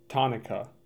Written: Tonica

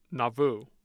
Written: nauvoo

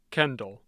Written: Kendall

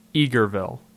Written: Eagerville

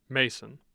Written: Mason

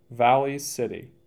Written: Valley City

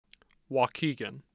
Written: Waukegan

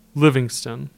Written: Livingston

